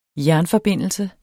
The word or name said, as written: jernforbindelse